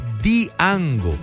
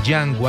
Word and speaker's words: Django: Hace días un señor, que yo no me atrevo a decirle terco, perdón, pero ni siquiera obstinado, pero insistía en que se pronunciaba correctamente Diango, y la D, aunque se escribe el originario del nombre de Django, hace parte del idioma romaní, aunque la D se escribe, no se pronuncia, y tal cual es Django con Corazón Mágico, aquí en Bésame Te Enamora.